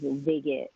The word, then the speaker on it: Vig.it: Other than that, there is one more thing I want to tell the audience is I've tried Vig.it, It's pronounced Vig.it, like Pig.it.